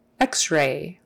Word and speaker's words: X-ray: You don't really say Ray, but you would just make sure it's kind of just barely there. Ray. X-ray. And the accent is on the X.